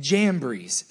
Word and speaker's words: Jambres: Verse 8 says these two men's names, and I looked up how to pronounce them so I didn't sound like an idiot. Jannes, which I feel bad that his mom named him that, and Jambres, same thing, opposed Moses.